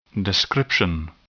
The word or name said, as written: description